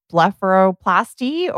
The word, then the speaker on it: blepharoplasty: Is it still, I'm going to butcher this name, but is it still blepharoplasty or is that the go-to or? – Yeah. And you said it perfectly.